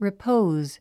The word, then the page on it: repose: ripóuz; リポーゥズ